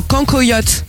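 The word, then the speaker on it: cancoillotte: Le cancoyote. – Oui. – Ça se prononce comme ça? – Oui, ça se prononce comme ça.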